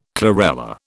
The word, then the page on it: chlorella: British English